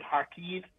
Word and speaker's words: Kharkiv: It is in Kharkiv, or as they pronounce it, Kharkiv.